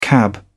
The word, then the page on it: cab: British English